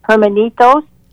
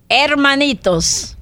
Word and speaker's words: hermanitos: Well, one of the things that is so important is to, along with feeding, and help me with the Spanish, hermanitos. – Hermanitos. – Hermanitos. – The brothers. H is silent, yes.